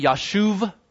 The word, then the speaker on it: Yashuv: The B is actually pronounced with a V, Yashuv, okay?